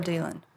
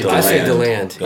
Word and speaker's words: DeLand: Is it DeLand? – Or DeLand. – How do you say it? I say DeLand. – Okay, that's what I think, too, but I've been told other things. – Yeah, we hear both, so.